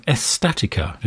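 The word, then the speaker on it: Estática: That's from his brand new album called, actually I think I've got the pronunciation slightly wrong, Estatica is the pronunciation.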